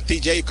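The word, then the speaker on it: Tejay: Se pronuncia TJ como Tijuana abreviado, pero él escribe diferente. Su nombre es Tejai con Y, TJ la fonética, Antone o Antón su apellido.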